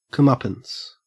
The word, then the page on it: comeuppance: Australian English